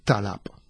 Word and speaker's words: talab: Imma jekk nieħdu l-kelma talab. Talab. Ara, jisni qed nenfasizza l-ewwel sillaba talab. Infatti jien nista' it-tieni sillaba indajjef ftit talab. U inti xorta tifhimni. Sawa? Imma jekk mill-banda l-oħra nafas fuq it-tieni sillaba u tiġi talab għaw biddilt il-sens u koll, sawa, għax l-aċċent biddiltu. Mela, għanna, per eżempju, kelma belti, belti, fuq l-ewwel sillaba, tiġi jiġi l-aċċent primarju.